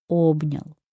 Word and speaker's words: обнял: Обнял или обнял? Вот такой вопрос. Конечно, обнял. Как запомнить это ударение? Как обнять человека так, чтобы было и приятно, и грамотно? Мне представляется, что в слове «обнял» «о» — это главное гласное, которое берет на себя ударение.